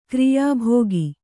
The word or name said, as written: ಕ್ರಿಯಾಭಾಗ